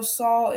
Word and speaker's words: Saul: You said it right, Saul.